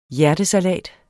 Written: hjertesalat

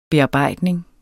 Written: BEARBEJDNING

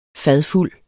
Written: fadfuld

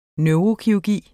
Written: neurokirurgi

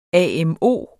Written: AMO

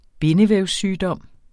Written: bindevævssygdom

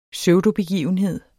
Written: pseudobegivenhed

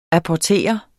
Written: APPORTERES

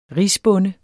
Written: risbonde

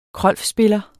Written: krolfspiller

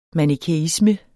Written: manikæisme

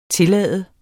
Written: tillade

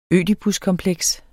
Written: ødipuskompleks

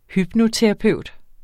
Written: hypnoterapeut

